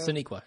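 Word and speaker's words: Sonequa: Soniqua? Is that Sasha? – Yeah, Soniqua, yeah. – Soniqua, okay. Well, damn. Just like it's spelled.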